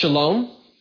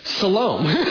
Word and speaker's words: Shalom: Because if I was Ephraimite, would I pronounce Shalom? No, it would be Salom. So don't put a sh sound in there, or some other, the sh sound will be gone.